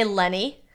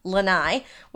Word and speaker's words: Lenni: which I always want to say Lenny, like Lenny Bruce, but it's Lenni, which I did not learn until I moved to Philadelphia.